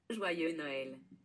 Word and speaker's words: Joyeux Noël: Joyeux Noël.